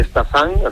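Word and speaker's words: Stassin: Estamos hablando de un delantero, el nombre de Stasin, así se pronuncia, este jugador belga muy jovencito.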